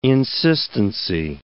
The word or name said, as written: insistency